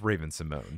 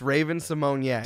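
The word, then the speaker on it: Raven-Symoné: It's just Raven Simone. – It's Raven Simonier. – Does she pronounce it Simone? – Yes, because there is an accent on the E.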